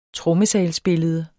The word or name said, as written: trommesalsbillede